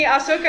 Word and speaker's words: Ahsoka: Watching some of the animated stuff, they do that, like in the Asuka episode of that Jedi Tales. They Ahsoka.